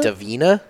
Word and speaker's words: Divina: How would you pronounce that? Divina? – Divina. It's the Divina? – Ave Maria Divina.